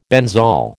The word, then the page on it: benzol: American English